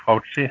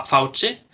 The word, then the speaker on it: Fauci: Jeg har tro til han eh, Hvor er det man eh, uttaler navnet hans? Eh, Fauci, Fauci i USA. Fauci, har jeg lært. Fauci.